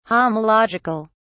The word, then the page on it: homological: British English